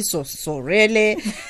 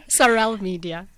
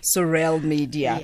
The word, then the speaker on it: Sorele: Joining me in studio is Stephina Zwane and Salamina Mosese, the founders of, please tell me how to pronounce this, Basotho, sometimes it would be Sorele, Sorele, help me out. – Sorel Media. – Got a twist to it. That is a sophisticated version, Sorel Media.